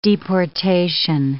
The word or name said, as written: deportation